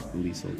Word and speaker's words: Liesel: Liesel kind of rhymes with Riesel.